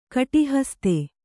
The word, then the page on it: ಕಟಿಹಸ್ತೆ: kaṭihaste